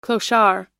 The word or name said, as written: clochard